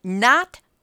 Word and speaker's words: Knot: So there is a tourism company called Not World Co. And it's spelled K-N-O-T, but it's got to be somebody's idea of a joke.